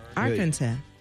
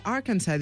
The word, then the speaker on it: Arkansas: Ell és d'Arkansas, però és que no vull dir Arkansas així, perquè no es pronuncia així. – Ah, no? – Arkansas. – Jo he dit sempre, tota la vida he dit Arkansas.